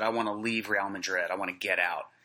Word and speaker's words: Real Madrid: Yeah, it's actually Real Madrid, I think is how you pronounce it.